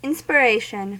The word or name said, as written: inspiration